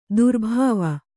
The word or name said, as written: ದುರ್ಭಾವ